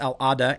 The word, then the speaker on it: Al-Awda: The ship called the Al-Awda, I don't want to mispronounce that, but I think it's Al-Awda, A-W-D-A,